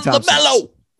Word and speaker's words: LaMelo: My son LaMelo rhymes with Jello.